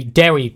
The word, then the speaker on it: Dewi: D-E-W-I. Sorry, not Dewey. Dewey Pritchard, Jim Brock, and Marjorie Jacobs.